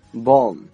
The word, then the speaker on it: bomb: OK，接下来呢一个单词叫做 bomb B-O-M-B，这个 B 啊在这里面不发音，所以啊不要读成 bomb 啊。